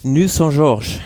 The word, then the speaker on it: Nuits-Saint-Georges: Im Prinzip wird es Nuits-Saint-Georges.